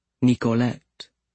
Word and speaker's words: Nicolette: She'd pronounced it the French way, Nicolette.